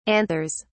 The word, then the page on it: anthers: British English